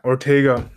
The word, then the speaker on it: Ortega: I think Artegio, if I pronounce his name properly, or Ortega or something. Yeah, Ortega, sorry, Armando Ortega.